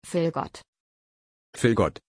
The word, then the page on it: vilgot: German